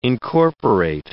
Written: incorporate

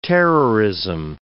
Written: terrorism

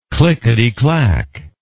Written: clickety clack

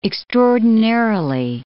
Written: extraordinarily

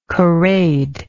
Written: corrade

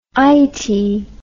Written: eytie

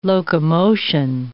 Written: locomotion

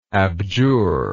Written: abjurer

